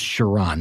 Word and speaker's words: Chiron: Now, I know it's Chiron, and my gut instinct was to pronounce that Chiron. I wanted to say Chiron. It's Chiron. That's the way I've heard many, many reviewers talk about it. It's Chiron. That's the way it is.